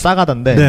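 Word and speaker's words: Sagada: 살라다? – 사가다. – 사가다. – 사가다. – 사가다. – 현지 발음은 사가다인데, 네, 네.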